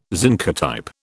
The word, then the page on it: zincotype: British English